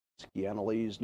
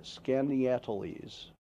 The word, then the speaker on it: Skaneateles: The lawsuit, filed in New York District for the Northern District of New York, Skaneateles, New York. I'm sorry, Skaneateles. I got to say that right.